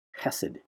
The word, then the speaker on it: chesed: It's spelled the way I spell it anyway, is C-H-E-S-E-D. chesed. Kind of like you're clearing your throat. Chesed. Kind of like that. Kind of looks like chesed or cheesed. But it's chesed.